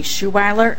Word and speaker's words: Schouweiler: I'm Dakota County Commissioner Nancy Schuweiler. That's spelled S-C-H-O-U-W-E-I-L-E-R.